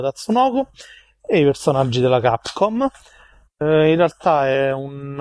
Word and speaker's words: Capcom: Potevi dire semplicemente Tatsunoko vs no, Capcom. Capcom. che l'avevo detto benissimo.